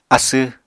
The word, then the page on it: asw: Apatani